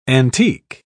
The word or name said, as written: antique